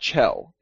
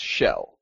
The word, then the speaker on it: Shel: It's spelled S-H-E-L. It's not Chell. It's Shell. Yeah.